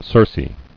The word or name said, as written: Circean